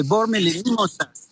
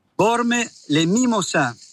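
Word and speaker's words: Bormes-les-Mimosas: Desde Francia, desde Bormes, Les Mimosas. – Ahí está, como bien pronunciaste. – A ver, repetilo entonces. – Bormes, Les Mimosas.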